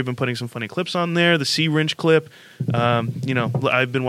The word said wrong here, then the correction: syringe: Sea Wrench. – Yeah. No, not even close. – Syringe.